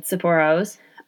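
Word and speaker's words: Tsipouro: So one recipe in this book calls for Tsipporo, which is a Greek grape-based brandy. And even from the company which is listed in the recipe, that company has multiple Tsipporos. For anybody wondering how you spell that, it's T-S-I-P-O-U-R-O, Tsipporo.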